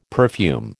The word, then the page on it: perfume: American English